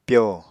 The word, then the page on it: pyo: Apatani